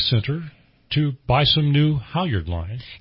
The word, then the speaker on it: halyard: It's called halyard.